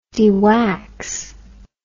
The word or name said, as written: dewax